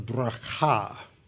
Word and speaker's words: Bracha: They take their valuables, their jewelry, they strip them of their clothes, and then they go to a place called the Valley of Bracha. That's the correct Hebrew pronunciation.